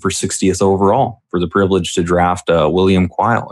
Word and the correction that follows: Cooley: or Cooley. I always get his name wrong. Yeah, I mean, Cooley, yeah. I